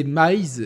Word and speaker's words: Maiz: Et donc, c'est Maze, M-A-I-Z. Vous pouvez plus aussi le dire Maze, mais on va respecter la, prononciation nord-africaine.